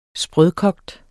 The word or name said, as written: sprød